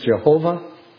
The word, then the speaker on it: Jehovah: Sometimes pronounced Yahweh, sometimes pronounced in the older versions Jehovah.